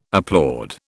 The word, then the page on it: applaud: British English